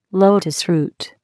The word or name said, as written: lotus root